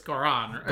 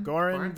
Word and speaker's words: Goran: It's Goran. – Goran. – Goran? – Goran Vishnik. – Goran Vish. – Goran rhymes with Lauren.